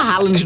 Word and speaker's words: Haaland: Caramba, aquele Haaland lá, a gente fala Haaland no Brasil, lá no país deles, parece que a pronúncia do nome dele é rola, só pra você ter ideia.